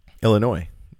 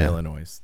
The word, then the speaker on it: Illinois: Is which one's the right way to say that? – Illinois. – No S. – No S. Not Illinois. They get really upset when you say that.